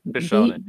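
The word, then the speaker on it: bishōnen: So I think there's a certain style, and I apologize for saying this wrong. It's B shonen, Bishonen, yeah. – B shonen, yeah, would B shonen, which literally translated means beautiful boy.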